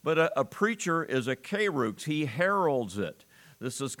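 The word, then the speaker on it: kairux (κῆρυξ): The word preach is a Greek word you pronounce kairux.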